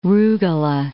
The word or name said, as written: rugola